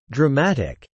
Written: dramatic

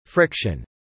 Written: friction

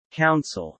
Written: council

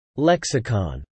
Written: Lexicon

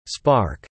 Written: spark

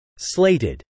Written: slated

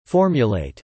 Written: formulate

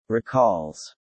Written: recalls

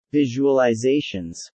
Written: visualizations

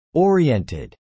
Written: oriented

